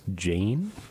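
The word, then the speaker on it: Jain: Also, between Bruno Mars and Paul Simon, we heard, I think her name is pronounced Jane, but it's J-A-I-N.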